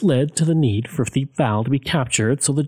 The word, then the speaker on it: Thiepval: Now, we're going to be talking about the Battle of Thiepval Ridge, and I've heard it pronounced Thiepval and Thiepval. I'm going to say Thiepval, because that just works the best for me with my Albertan accent, and I hope it works for you guys too.